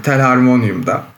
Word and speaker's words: telharmonium: Doğru telaffuz ettiğimi inanarak başlıyorum telharmonium'da.